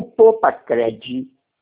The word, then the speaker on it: PoupaCred: Não, o Poupa Cred. – Ah. – Esse Cred, o D é mudo. – PoupaCred com D mudo, exatamente.